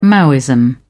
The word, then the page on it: maoism: British English